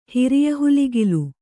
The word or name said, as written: ಹಿರಿಯಹುಲಿಗಿಲು